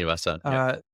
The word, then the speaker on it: Srinivasan: There is the book that Balaji Srinivasan, I'm not sure I pronounced his last name right. – Srinivasan, yeah.